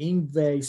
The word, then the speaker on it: Yngwie: And tonight, with me, and I know how to say his name because of Yngwie Malmsteen. Tonight, with me is DJ Yngwie Swain.